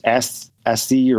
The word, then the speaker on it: ASCII: A-S-C-I-I. I thought it was just ASCII or when I was a kid, but I guess it's pronounced ASCII.